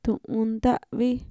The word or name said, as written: tu'unnta'vi